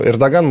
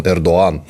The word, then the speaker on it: Эрдоган: По-нашему и э, Эрдоган мы неправильно произносим, потому что нет «г» у них оно не читается, он Эрдоан.